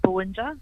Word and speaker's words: Buwindja: I believe you are saying it correctly, Buwindja Collection.